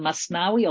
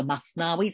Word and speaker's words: Mathnawi: is called the Masnawi or the Mathnawi, depending on what sort of pronunciation you want to adopt.